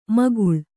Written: ಮೇಖಳೆ

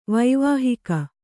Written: ವೈವಾಹಿಕ

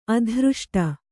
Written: ಅದೃಷ್ಟ

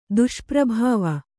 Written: ದುಷ್ಪ್ರಭಾವ

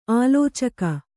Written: ಆಲೋಚಕ

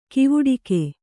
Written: ಕವಡುಗ

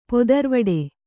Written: ಪೊದರ್ವಡೆ

